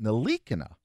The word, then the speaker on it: Ntilikina: Enes Kanter of the Knicks, of course, got nose to nose with LeBron James after LeBron and rookie point guard Frank Ntilikina, or as Frank calls it, Ntilikina. Yeah, different pronunciation, but Ntilikina is easier for you and I to say.